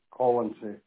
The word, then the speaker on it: Colonsay: C-O-L-O-N-S-A-Y, Collinsy.